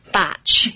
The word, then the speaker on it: Bach: I actually say Batch. It's spelled B-A-C-H. And some people say Bach, some people say Batch. It's just the pronunciation I learned right from the beginning, so that's what I use.